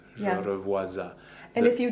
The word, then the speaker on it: revois: Je revois. Uh, the S is silent. Just in passing, the OI combination is wa in French.